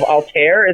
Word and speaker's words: Altair: Like, my one of my favorite stories was Large-Breasted Ninjas of Altair.